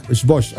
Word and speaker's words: Xbox: Oigan, pero sí se pronuncia Xbox. Xbox. Ahí dice.